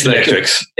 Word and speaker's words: Xmetryx: Is it Xmetryx? – You're very close. Xmetryx. – It's Xmetryx.